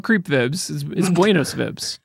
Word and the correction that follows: buenas vibras: Only buenas vibras. Yes, thank you. Buenas vibras. Okay. I just want to make sure everyone knows how to pronounce that.